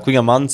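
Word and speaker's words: kouign-amann: Voilà, Queen Amann, on le dit en breton, c'est comme ça. – D'accord. – Comme ça que ça se prononce.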